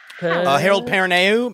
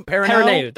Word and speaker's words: Perrineau: I can never pronounce his last name. – Harold Perrineau. – Perrineau. That's it. Perrineau.